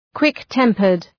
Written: quick-tempered

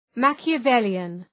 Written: Machiavellian

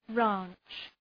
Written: ranch

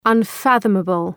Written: unfathomable

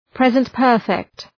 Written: present perfect

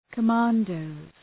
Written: commandos

